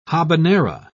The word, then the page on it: habanera: British English